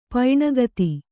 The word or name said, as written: ಪೈಣಗತಿ